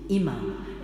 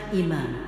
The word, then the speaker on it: imam: On y dit imam, et non pas imam, comme certains le prononcent. Deux M, comme maman, donc imam.